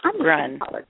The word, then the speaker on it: Gruhn: Gruhn is her last name, Grown, how would you say that? – What is it? – G-R-U-H-N. Catherine. – Grun. – Catherine Grun. Okay.